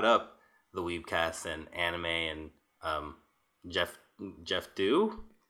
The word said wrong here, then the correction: Geoff Thew: Did I say his name right? – Geoff Thew. – Geoff Thew.